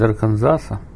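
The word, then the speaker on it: Arkansas: Из Арканзаса. Здесь это читается Арканзас. С последнее не читается.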